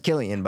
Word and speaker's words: Cillian: He says Cillian? – Yeah. – Who's he? – Cillian. Cillian himself? – Yeah. – Someone said, how do you say it? And he goes, it's Cillian.